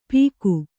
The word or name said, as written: ಪೀಕು